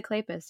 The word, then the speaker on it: Kleypas: So one sexy and one life threatening good girl moment in this book, which it's Lisa Kleypas. So you should expect that. I have like butchered her name. Like I was like Lipa. I've like called her Lika.